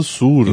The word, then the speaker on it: SVR: Comando SUR. Então, né? a gente lê comando SUR, mas se escreve comando SVR. – É, os, os romanos escreviam U com V, né?